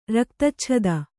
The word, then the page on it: ರಕ್ತಚ್ಛದ: raktacchada